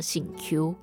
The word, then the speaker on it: 媳妇: 媳妇她的客语呢就叫做醒 Q，然后帮忙的客语读作填素，也是一个还蛮特别的词汇，可以稍微记下来。